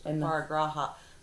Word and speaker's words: Aparigraha: That's how I've heard it pronounced. Aparigraha.